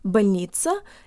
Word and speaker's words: больница: guys, today you'll learn how to pronounce больница and вбольница, or hospital and in the hospital. The O in this word sounds like an A-flavored schwa.